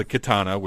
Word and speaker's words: Khetanna: But uh, it's time now, I think, to sit down and really talk about the Khetanna, which is the pronunciation we're going to stick with because it yes. just sounds cool.